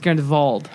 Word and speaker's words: Grindelwald: I believe it is supposed to be pronounced Grindelwald.